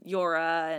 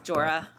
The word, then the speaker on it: Jorah: The only other deaths I think I'm pretty familiar with was Theon and Jorah and then Lyanna. – You pronounced the J. – Jorah.